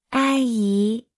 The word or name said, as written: 阿姨